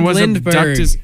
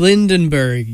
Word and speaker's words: Lindbergh: Charles Augustus Lindbergh Jr. was the eldest son of aviator Charles Lindbergh. – You could have said Lindbergh. You said Lindenberg, you dunce.